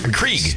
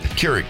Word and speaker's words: Keurig: Johnny brought in a coffee maker because Krieg. – Krieg. – Keurig.